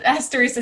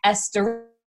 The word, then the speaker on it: asterisk: it is not pronounced like asterisk, as in the cartoon character Asterix and Obelix.